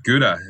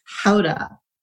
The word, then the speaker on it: Gouda: I don't remember where it was for me, but I said, oh, yeah, Gouda. And then someone said, it's Howda.